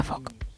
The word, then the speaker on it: folk: O sea, su regla clave es que la letra L es muda. – Anda. – Con lo cual se pronuncia folk.